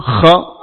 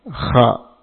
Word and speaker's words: kha: hukumnya adalah izhar halqi karena huruf kha adalah huruf tenggorokan. Kha ini keluar dari ujung tenggorokan, maka izharnya dinamakan dengan izhar halqi. Kha cara pengucapannya boleh dengan kotor dan bersih. Kha, kha, ini dua-duanya boleh insyaallah.